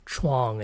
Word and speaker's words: Chuang: But in any case, oftentimes I write it out, even though my name is technically one syllable. Chuang is one syllable.